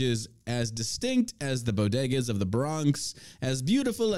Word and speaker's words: bodegas: The comment, which included Biden mispronouncing bodegas as Bogota.